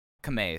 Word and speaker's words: Kamei: Is it Takeo Kamei or Takeo Kamei? – Kamei is how it's pronounced. – You were saying Kamei, and I need to get on that. You were correct at first.